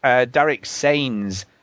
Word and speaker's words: Sains: Derek Sains. Rhymes with brains, mains, fanes, etc.